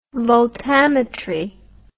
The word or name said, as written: voltammetry